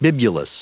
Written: bibulous

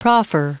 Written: proffer

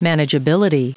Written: manageability

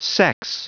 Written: sex

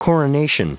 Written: coronation

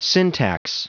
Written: syntax

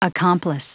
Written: accomplice

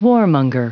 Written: warmonger

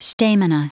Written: stamina

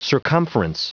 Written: circumference

